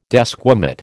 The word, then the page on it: desquamate: American English